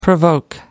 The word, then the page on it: provoke: American English